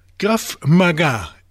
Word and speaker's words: Krav Maga: Krav Maga. Zo zeg ik het goed, geloof ik.